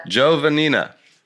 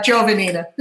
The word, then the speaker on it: Giovannina: Giovannina. You know, those Italian names, there's a lot of syllables, and everybody tries to pronounce each one. – But you could kind of smooth it out. Giovannina.